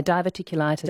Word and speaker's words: diverticulitis: Di in Mandurah says, what about diverticulitis? Is that, I haven't pronounced that correctly. – Yeah, diverticulitis.